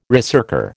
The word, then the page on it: ricercar: American English